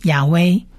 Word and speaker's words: 亚威: 那耶和华这个四个字的这个神的名字，我们其实在第一堂课的时候已经讲过了，它其实你就可以念成亚威，或者是在犹太人里头有些人用 Adonai 来取代亚威 Roy，耶和华亚威，我的牧人 Roy，我的牧者 Roy，我的牧人 Roy，亚威 Roy，亚威 Roy，耶和华是我的牧者。